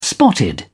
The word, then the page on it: spotted: British English